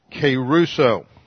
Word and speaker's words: kerusso: and there's the verb keruso. Looks like this in the Greek. K-E-R-U-S-S-O, long O.